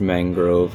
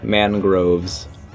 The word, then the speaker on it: mangrove: It is actually pronounced mangrove, like the tree. I should know. I said it 44 times. – How did I pronounce it? – Mangroves.